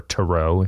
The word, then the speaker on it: tarot: I am in no way an expert in reading tarot or tarot, if you want to pronounce it that way.